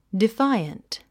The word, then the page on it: defiant: difáiənt; ディファイアント